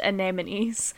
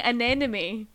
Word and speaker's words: anemone: I've been saying anemone my whole entire life, and it's anemone, and it feels very strange on my tongue.